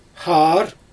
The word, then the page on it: xaar: Xyzyl